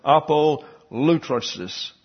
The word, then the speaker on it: apolutrosis: The word redemption translates the Greek noun apolutrosis. Apolutrosis is the way it's pronounced with the accent on the upsilon, to let go free from a ransom.